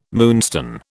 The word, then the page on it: moonstone: British English